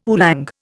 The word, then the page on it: oolong: Indian